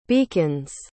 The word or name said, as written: beacons